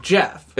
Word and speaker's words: Geoff: G-E-O, like, capitalized. – Well, I think it's still pronounced Jeff.